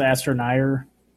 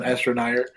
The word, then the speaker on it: Astroneer: I think it's pronounced Astroneer. – You're right, my bad. Astroneer.